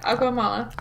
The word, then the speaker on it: Agua Mala: Let's start off with two from Agua Mala, which I love saying. I'm probably absolutely butchering the Spanish, but... – No, you've got it right. Agua Mala.